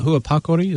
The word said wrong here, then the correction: Pua Parakore: is that what I'm hearing? – Pua Parakore. – Parakore, so, okay, yep.